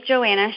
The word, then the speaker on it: JoAnna: And my name is JoAnna Shivers, and just similar to JoAnna Gaines, who is right down the street in Waco.